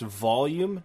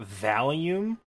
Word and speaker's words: volume: To build on that, when I was a kid, up until the age of like 17, nobody told me the thing that determines how loud something is pronounced volume, not valium.